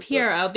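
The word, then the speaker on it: Pierrot: I pronounce it Pierre because they don't deserve the proper pronunciation. – What is the proper pronunciation? – Probably Piero. – Yeah, it's Piero after the clown, because that's why they have a clown mascot for their studio.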